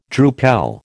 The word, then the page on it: drupel: American English